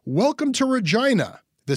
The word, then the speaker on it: Regina: And that's how we found out that it was a family show and he shouldn't do jokes about Regina rhyming with vagina.